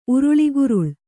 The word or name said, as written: ಉರುಳಿಗುರುಳ್